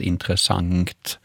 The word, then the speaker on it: intressant: Det här uttalet då som Nanna nämner, intressant, det finns också belagt i man kan slå upp i SOB och se att det här har kunnat uttalas intressant med ett ng på slutet.